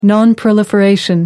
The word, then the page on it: non-proliferation: American English